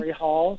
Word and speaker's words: Katori Hall: Katori Hall.